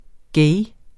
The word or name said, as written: gay